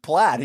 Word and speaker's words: plaid: And one of our friends said, like, pronounced plaid played. – Oh, like they had only been reading it their whole lives. – Yeah. And he was like, played. And we were like, that's pronounced plaid. He's like, no fucking way. That's plaid.